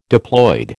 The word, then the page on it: diploid: American English